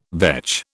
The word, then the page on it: vetch: British English